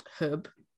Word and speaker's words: Hwb: I visited that 4 times, and I'm gonna say it's pronounced Hub, which HWB, but I think it's called Hub in Narberth in Wales.